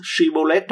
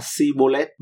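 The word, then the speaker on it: Shibboleth: không thể phát âm chữ Shibolet trong tiếng Do Thái, họ nói là Sibolet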